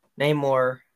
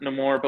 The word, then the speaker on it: Namor: It's pronounced both ways. I don't. – Yeah, it's both. He he pronounces it Namor, but like every other character calls him Namor, so I don't feel bad about calling him Namor.